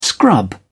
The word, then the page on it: scrub: British English